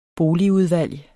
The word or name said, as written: boligudvalg